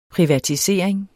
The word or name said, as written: privatisering